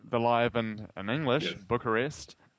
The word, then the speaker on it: Bucharest: I remember seeing it in live in Bucharest. Is that how you pronounce it? – Yes. Well, you would pronounce it like that. Eastern Europeans pronounce it differently, but yes.